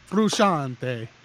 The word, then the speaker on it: Frusciante: Josh Frusciante is back after 10 years. – Frusciante. Frusciante. That's how you pronounce it. I want to say it like that from now on.